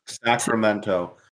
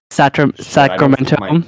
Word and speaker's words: Sacramento: Sacramento. – Sacramento. – I think my audio. – We'll find out. they're from Sacramento, and Lady Bird is like, Mom, do I look like someone from Sacramento?